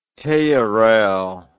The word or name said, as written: theorell